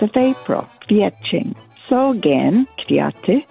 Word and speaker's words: kwiaty: Polish word for flowers, plural, is kwiaty, spelled K-W-I-A-T-Y. Again, that's pronounced kwiaty.